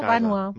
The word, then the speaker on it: vànova: No es pronunciava la segona ve baixa. – Ai, que no, vànova.